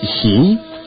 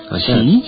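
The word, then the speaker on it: Xi (洗): And to wash is XI with the little... – a little U? – Yeah. – Then there's Xi. – Xi. – Xi. – Yeah, that's exactly how it's pronounced. – And round is Yuan. – What's that? All right, Y-U-A-N with a rising thing over the A.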